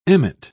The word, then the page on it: emmet: British English